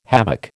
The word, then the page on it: havoc: American English